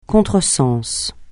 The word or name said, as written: contresens